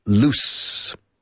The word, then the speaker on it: loose: The dog is loose. Yes, loose. Again, loose. Es una sonido de U pura, U española. Y S fuerte, loose.